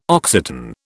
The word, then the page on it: Occitan: British English